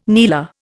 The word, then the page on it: kneeler: Indian